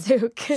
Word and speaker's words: Sook: Sook. It's okay. It's a hard one. Sook. Like, people always mess it up.